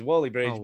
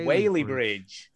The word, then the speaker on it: Whaley Bridge: Susan has corrected me because she does live in the vicinity of what I thought was Whaley Bridge, but apparently it's pronounced Whaley Bridge.